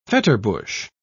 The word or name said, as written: fetterbush